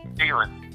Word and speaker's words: Thielen: Thielen.